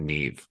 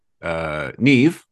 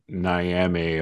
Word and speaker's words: Niamh: It's a tricky, so one of the characters in the book, one of the prominent characters, Neve, but it's spelt N-I-A-M-H, which is a traditional Irish name, but it's pronounced Neve. So like a N-E-V-E or N-E-A-V, however you want to spell it, I guess, you know, phonetically, but beautiful name. But yeah, I have to think that you're going to hear Niame a lot, or Neum, or I don't even know what to, you're going to hear that a lot, I'm sure.